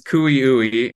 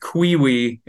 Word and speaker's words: Cui-ui: So the tribal pronunciation is Kooyooe, but Cui-ui is how we refer to the fish.